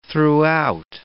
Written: throughout

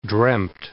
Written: dreamed

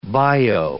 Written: bio

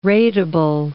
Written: rateable

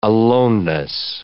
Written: aloneness

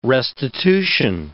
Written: restitution

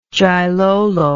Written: Jilolo

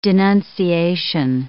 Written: denunciation

